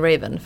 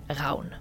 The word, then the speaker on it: Ravn: Men det är ett danskt namn från början och betyder Raven på danska, så korp på svenska och på danska uttalas det Raun.